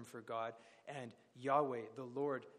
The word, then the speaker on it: Yahweh: Yahweh is how they try to pronounce it by inserting vowels.